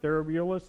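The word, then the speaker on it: Thrasybulus: and he sent a herald to Therobulus, Theribulus, that's how you say it.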